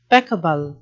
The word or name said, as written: peccable